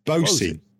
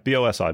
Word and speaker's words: Bosi: Bosi. Yeah, B O S I, Bosi.